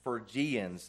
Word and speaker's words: Phrygians: The people of Colossae were mostly Phrygians. That's how you pronounce that word. I had to look it up today again.